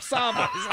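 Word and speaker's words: Tsavo: The Ghost in the Darkness was all based on the man-eating lions of Savo. You don't pronounce the T, I don't think.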